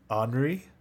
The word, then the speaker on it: Anri: It's called Last Summer Whisper by Henri. Yeah. – Some J-pop. Oh, sorry, who? – Henri. – Henri. How do you spell that again? – A-N-R-I.